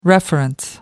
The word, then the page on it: reference: American English